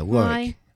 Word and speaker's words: Warwick: All right, we're going to kick this off with Dickie Doyle on W-Y-E, out of Warwick, Rhode Island. Is that how you pronounce it? – Yeah, Warwick. Well, if you're from Rhode Island, you go Warwick.